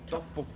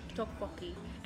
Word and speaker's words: Tteokbokki: Tteokbokki. – Tteokbokki.